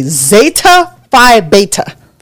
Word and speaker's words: Zeta Phi Beta: If you are a Zeta, what they don't play about is you are supposed to say Zeta Phi Beta. – For real? – Yeah. – Zeta Phi Beta. Every time. – But not the duh. Like, you're supposed to pronounce the Z, the T, and then the B.